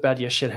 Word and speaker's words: Badiashile: Uh, I mean, in terms of Badiashile himself, very good pronunciation, by the way.